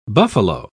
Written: buffalo

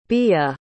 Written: bia

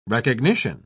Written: recognition

